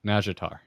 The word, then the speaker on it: Nazjatar: Just think of it as like a soft J, like Nazjatar.